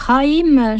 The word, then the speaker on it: Jaime: À 16 ans, j'aime, ou Jaime, je ne sais pas comment il faut prononcer, J-A-I-M-E, j'ai envie de le prononcer à l'espagnol, Jaime. On va, bon, peut-être qu'il y aura des protestations.